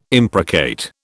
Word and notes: imprecate: British English

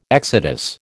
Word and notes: exodus: American English

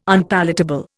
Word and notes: Unpalatable: Indian

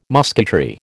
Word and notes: musketry: American English

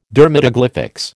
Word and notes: dermatoglyphics: American English

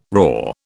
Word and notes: roar: British English

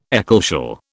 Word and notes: ecclesial: British English

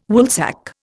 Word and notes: Woolsack: Indian

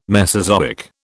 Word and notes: Mesozoic: British English

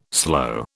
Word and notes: slow: British English

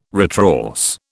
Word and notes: retrorse: British English

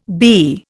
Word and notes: BE: Indian

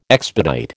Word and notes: expedite: American English

